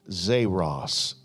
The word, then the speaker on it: xeros: That word withered is the word Zeros, Zeros. It's spelled X-E-R-O-S, just in case you wanted to write that down.